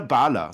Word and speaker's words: Balor: Dieser Balor, so wird er ausgesprochen, das ist ein Held der keltischen Mythologie, Herr Flöter, das weiß man aber.